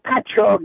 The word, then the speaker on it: Patchogue: How do you say that, New York? – That's Patchogue, New York.